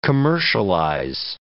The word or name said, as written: commercialize